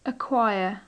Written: acquire

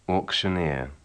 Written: auctioneer

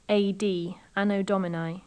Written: anno domini

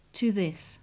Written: to this

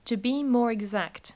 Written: to be more exact

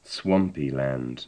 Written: swampy land

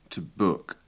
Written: to book

